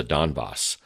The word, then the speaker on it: Donbass: I believe it's pronounced the Donbass, the parts of eastern, extreme eastern Ukraine that are quite loyal to Russia on every possible level.